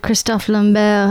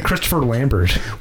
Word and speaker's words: Christophe Lambert: I kind of clued in when you said Christophe Lambert. I was like, what? That's how you say his name? I had no idea. – Yeah, for all these years, I've been saying Christopher Lambert.